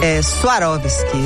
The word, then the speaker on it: Swarovski: Ela fala Swarovski, essa é a pronúncia correta aí para quem quiser ser precioso.